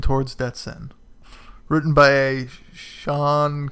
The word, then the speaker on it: Shaun: It's spelled Shaun, but I think it's pronounced Sean.